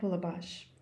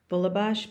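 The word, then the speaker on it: Bulebosh: Nanette Bulabash. – And can you spell your first name? – N as in Nancy, A, N as in Nancy, E-T-T-E. Bulabash, B as in boy, U-L-E, B as in boy, O-S-H.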